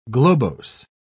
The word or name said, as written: globose